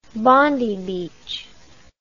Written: Bondi Beach

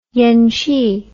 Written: yenchi

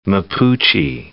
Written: Mapuche

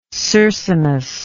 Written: Circinus

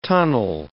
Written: tunnel

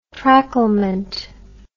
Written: tracklement

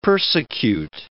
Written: persecute

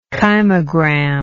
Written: kymogram

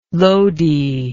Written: loady

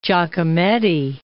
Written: Giacometti

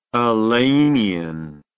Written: alleynian

